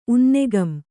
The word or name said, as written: ಉನ್ನೆಗಂ